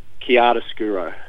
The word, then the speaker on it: chiaroscuro: Okay, it's an Italian word, and my Italian pronunciation is not that great, but I believe it's chiaroscuro.